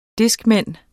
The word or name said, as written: discman